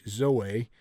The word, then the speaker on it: zoe: The word is Z-O-E, but it is actually pronounced Zoe.